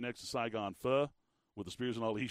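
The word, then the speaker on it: phở: Main Gate Square, Main Gate Plaza, if you will, right next to Saigon Pho, or Pho, if you're being, you know, proper with the pronunciation of it.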